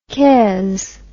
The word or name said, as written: quinze